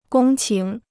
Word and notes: 公顷: gōng qǐng

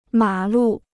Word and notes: 马路: mǎ lù

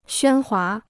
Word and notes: 喧哗: xuān huá

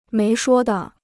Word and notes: 没说的: méi shuō de

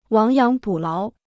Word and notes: 亡羊补牢: wáng yáng bǔ láo

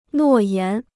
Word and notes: 诺言: nuò yán

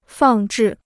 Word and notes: 放置: fàng zhì

